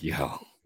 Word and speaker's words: Yeo: Is that how you pronounce it? Yeo? Y-E-O is Yeo. Okay. – Yeah, yeah. I was listening to our buddy DeCamera the other day, and he's like, Mike Yao? – Yeah.